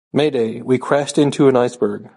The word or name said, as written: crashed